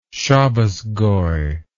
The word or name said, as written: Shabbas goy